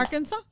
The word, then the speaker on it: Arkansas: Lo que sí hice, me fui a Arkansas. ¿Cómo se dice en español? – Arkansas. – Arkansas. – Arkansas. Arkansas. Ajá, Arkansas. Me fui, me fui a Arkansas por dos semanas me fui a las pinches montañas o a los cerros.